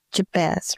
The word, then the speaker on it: Jabez: Well, I was just thinking about Jabez, right? That's how you pronounce it.